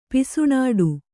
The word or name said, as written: ಪುಸಿನುಡಿ